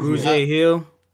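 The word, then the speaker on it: Grugier-Hill: I mean, what is it, Gregaire Hill? Is that how you pronounce that correctly? – Kamu Grugier Hill. – Wow. I was way off.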